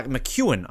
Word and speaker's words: McEwen: Because obviously with Kylie Masse in there, but our own Kaylee McCowan she has uh, McEwen, I should say. That's how you pronounce her name.